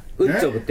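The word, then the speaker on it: WOOD JOB!: のスペルをウッドジョブと書いてあるでしょあれ、ね、ウッジョブって読むんですけど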